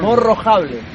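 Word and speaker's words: Morro Jable: Morro do Cabo, ¿cómo se pronuncia? – Morrojable. Ahí, muy bien. – Ahora sí. – Muy bien. – No Jable, Jable con J.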